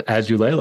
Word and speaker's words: Azoulay: So Azoulay, like Azoulay on the beach. My name is Marc Azoulay.